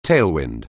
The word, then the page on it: tailwind: anglais